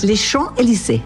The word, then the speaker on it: Champs-Élysées: Les Champs-Élysées.